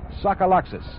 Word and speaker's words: Tsagalakis: He spells his name T-S-A-G-A-L-A-K-I-S and pronounces it Sokolaks.